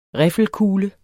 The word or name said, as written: riffelkugle